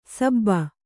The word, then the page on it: ಸಬ್ಬ: Kannada